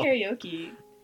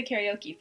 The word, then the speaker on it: karaoke: karaoke. – So you know how we so we say karaoke for like singing to to music, you know. Um, but in Japanese, it's pronounced karaoke,